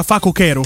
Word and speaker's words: facocero: Si dice facocero e non facocero. Ma davvero? In italiano. No, è vabbè, così. facocero proprio pare. – Anzi, in realtà si può usare anche la pronuncia greca facochero.